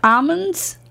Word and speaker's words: almond: These two popular foods are tricky because the L is silent in both. – Almonds?